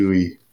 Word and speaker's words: Louis: It's Louis. It's just Louis, like Louis Anderson of Family Feud.